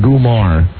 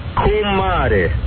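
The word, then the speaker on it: comare: Say, repeat after me. Kumare.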